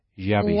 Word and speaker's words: yobbish: Yabish. – Yabish. – Yeah, with a Y. – Yeah. Yabish. – Y-O-B-B-I-S-H. – Yabish. – Yabish, exactly. Okay, this word's funny because a lot of people say, how do you say trabajo? – Work.